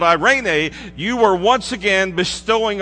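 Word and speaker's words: eirene: Well, in the New Testament Greek word equivalent from shalom is a little word, once again, that sounds nothing like peace, but it's pronounced irene.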